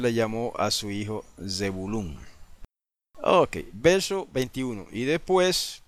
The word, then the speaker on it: Zebulún: Es lo que en las Biblias tradicionales se llama Zabulón, ¿verdad? Eso viene del griego, esa pronunciación. Zabulón viene del griego. En hebreo se dice Zebulún, con Z al principio.